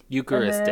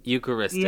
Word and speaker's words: Eucharistic: Things took a turn after the priest got to the Agnus Dei, which Agnus Dei is a chant used in Eurochrist Eucharistic. – Eucharistic. – And then, Eucharistic. – Yeah, and liturgy.